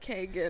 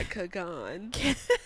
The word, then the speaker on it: Kagan: I don't know, child, but I know her last name is pronounced Kagan and okay. Not Kagan.